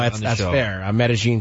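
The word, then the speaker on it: Medellín: Medellin, yeah. And the guy who... It is pronounced Medellin. – Is it? – Yeah, they mispronounce it on Entourage for seasons.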